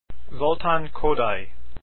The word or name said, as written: Kodaly